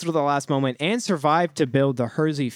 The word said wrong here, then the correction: Hershey: food empire. – Hershey. – Oh, is it a typo? – No, it's Hershey. – Milton Hersey. – That's how you pronounce Hershey.